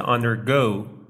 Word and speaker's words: undergo: همونطور که از تلفظ نوشته شده اون هم میتونین ببینین، استرس این کلمه روی سیلاب دومه، یعنی اون رو به صورت undergo تلفظ میکنیم.